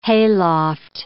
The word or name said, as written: hayloft